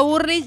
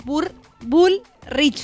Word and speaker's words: Bullrich: Yo digo Burlich y es Bullrich.